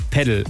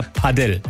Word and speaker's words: Padel: Ich würde sagen, ich sage immer Padel, man kann aber auch Padel sagen. Ist da ein bisschen die Sache, woher man kommt. Ich glaube, im Spanischen spricht man es eher Padel aus, aber ich glaube, solange man nicht Padel sagt, dass ihr dann langzieht, ist, glaube ich, ist man auf der richtigen Seite.